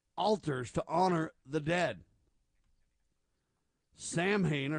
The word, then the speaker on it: Samhain: Now, that's the wrong pronunciation. They say it's an ancient Celtic festival marking the end of the harvest season, the beginning of winter. Pronounce Samhain, I think is how you say it.